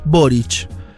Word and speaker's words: Boric: dice, es Boric. – No porque se les cantó a los chilenos, sino porque es un apellido yugoslavo, se pronuncia con CH al final, Muy bien.